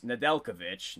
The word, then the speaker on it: Nedeljkovic: Alex Nedeljkovic, not too bad.